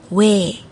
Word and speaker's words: 외: 那第一个呢是朝上的，左边是朝上的 o，跟右边是一个一组合起来的发音，它念 way way。